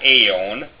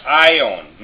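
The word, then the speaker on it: aion: The first one, alpha iota, it's pronounced I. It's not A. It's I, as in the Greek form ion. It's not aeon. It's ion, meaning age in the scriptures.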